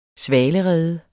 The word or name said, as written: svalerede